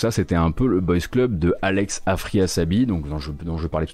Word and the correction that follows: Afrasiabi: Je prononce bien son nom Est-ce que je prononce bien son nom Afrasiabi, pardon.